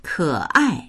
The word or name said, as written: ke3ai4